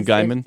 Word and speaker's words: Gaiman: Gaiman, or Gaiman, however you... – Neil Gaiman? – Yeah, it's correct to pronounce it.